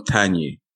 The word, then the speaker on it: Tagne: We call them Tanyi. – We call them what? – We call the men Tanyi and Tanyi. The ladies. – Tanyi. – And Tanyi. Okay. Yeah. It's spelled T-A-G-N-E, but okay. Tanyi.